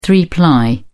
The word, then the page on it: three ply: British English